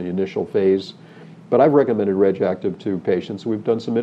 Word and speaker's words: RegActive: It's spelled R-E-G apostrophe A-C-T-I-V, RegActive. – RegActive.